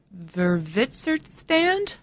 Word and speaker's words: Witwatersrand: He's the head of the journalism school at the University of, I'm going to try to pronounce this correctly, Witwatersrand.